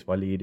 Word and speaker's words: W'leed: But actually, in this case, Waleed is, it's got that sort of dropped syllable in the original Arabic, so the apostrophe is actually usually how you indicate that.